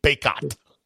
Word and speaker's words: Bacot: How do you say his last name? Ben. I never said it right. I can't. Because he's from Richmond. – Oh, yeah. Armando Bacot. Yeah. It's like bacon, but with a T. Bacot.